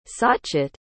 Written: sachchit

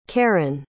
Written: carron

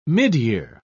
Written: midyear

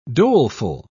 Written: doleful

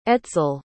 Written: etzel

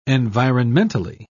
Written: environmentally

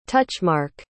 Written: touchmark

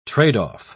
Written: trade-off

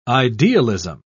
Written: idealism

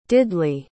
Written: diddley

